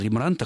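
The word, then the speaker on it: Rembrandt: Я никогда не слышал Рембрандта, хотя потом уже сообразил, что где-то когда-то читал о том, что такой вариант тоже возможен. В голландском языке, собственно, только ударение на первый слог – Рембрандт.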